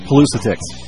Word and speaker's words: Pelusitics: Today in the studio with me, I have the Pelusistics website. Is that how you say it? – Pelusitics. – Pelusitics. Sort of like politics. – Exactly.